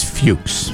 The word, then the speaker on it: Fuchs: I believe it's Fuchs. – That's what people who have that name pronounce it as. – Yeah, I had a there's no one who can confuse it for what it looks like.